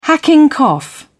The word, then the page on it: hacking cough: British English